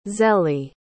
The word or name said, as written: zélie